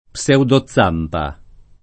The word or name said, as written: pseudozampa